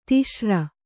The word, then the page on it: ತುಷಾರ: tuṣāra; tush'āra